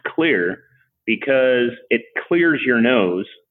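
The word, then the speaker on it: Xlear: And so, you know, then to paying homage to the xylitol, we changed out the C for an X and it's pronounced Clear.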